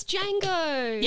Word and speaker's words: Django: The D is silent. – Meep, meep, meep. – I so had that. It's Django.